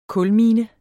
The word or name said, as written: kulmine